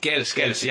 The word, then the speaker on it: Kielce: Oye, allí en Kielce, ¿no? Lo digo bien, la ciudad en la que estás. – Kielce.